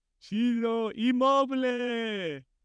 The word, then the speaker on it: Ciro Immobile: Ciro Immobile.